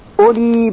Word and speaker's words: أُولِي: فَإِذَا جَاءَ وَعْدُ أُولَاهُمَا أُولَاهُمَا، که در اینجا واو بعد از همزه خونده میشه أُولَاهُمَا، بَعَثْنَا عَلَيْكُمْ عِبَادًا لَنَا أُولِي بَأْسٍ أُولِي بَأْسٍ. در این مثال واو بعد از همزه خونده نمیشه. أُولِي بَأْسٍ شَدِيدٍ فَجَاسُوا خِلَالَ الدِّيَارِ وَكَانَ وَعْدًا مَفْعُولًا.